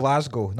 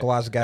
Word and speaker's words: Glasgow: It annoys me how Americans say Glasgow. American listeners, Glasgow. – It's Glasgow, not Glasgow.